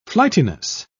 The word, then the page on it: flightiness: British English